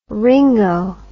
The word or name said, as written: Ringo